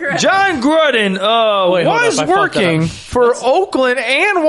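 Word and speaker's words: Jon Gruden: He goes, John Gruden.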